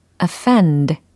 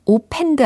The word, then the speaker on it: offend: O F F E N D 바로 이 철자인데요. 발음은 offend 그렇죠. offend 아니고 offend offend offend 네, 이와 같이 이음절에 강세를 주시면 되겠습니다.